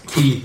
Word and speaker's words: Quay: Key is pronounced. – Going to say a key. I always say Quay.